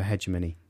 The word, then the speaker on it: hegemony: Hegemony, it's both are right. – It's a question of where you're from. It's a transatlantic thing. So Pronunciation Book goes for... – Hegemony. Hegemony. Hegemony. – Hegemony, no. – And he puts the accent on the second syllable.